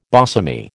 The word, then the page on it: bosomy: American English